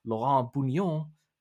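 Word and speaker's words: Laurent Bugnion: Laurent Bougnon. Yes, that's how you pronounce it.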